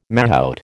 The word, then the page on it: mahout: American English